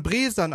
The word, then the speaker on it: Bresan: Ach, die spricht man Bresan aus.